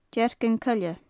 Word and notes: deargan coille: gaelic

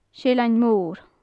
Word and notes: seillean mor: gaelic

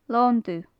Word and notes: lon dubh: gaelic

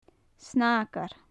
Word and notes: snaigear: gaelic

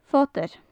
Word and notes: fodair: gaelic

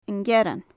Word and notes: an gearran: gaelic

